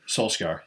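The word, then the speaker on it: Solskjær: so how do you pronounce his name, Solskjaer? Solskjaer, yeah.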